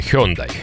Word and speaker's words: Hyundai: Давай для початку будемо правильно говорити Hyundai. – Hyundai, Genesis.